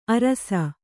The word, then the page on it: ಆರೈಸು: āraisu